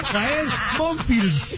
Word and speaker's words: Gael Monfils: nuestro deportista a destacar, primero de septiembre, es Gael Monfils. Gael Monfils.